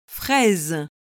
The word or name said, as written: fraise